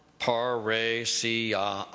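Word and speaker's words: parrhesia: And it's the Greek paresia, pronounced paresia. I'm going to just stick with English.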